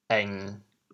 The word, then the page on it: 翁: êng1; 姓; 潮阳